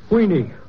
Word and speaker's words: Weenie: Oh, you're hot stuff, you are, Heine. – Weenie, not Heine. Weenie rhymes with meanie.